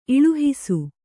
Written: ಇಳುಹಿಸು

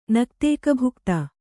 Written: ನಕ್ತೈಕಭುಕ್ತ

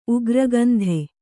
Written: ಉಗ್ರಗಂಧ

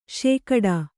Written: ಶೇಕಡಾ

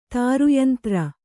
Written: ತಾರುಯಂತ್ರ

